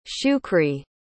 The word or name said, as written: shukri